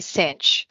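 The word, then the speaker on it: Cynch: So my friends actually call me Cinch. Like when you say it's a cinch, but it's spelled with a Y instead of an I.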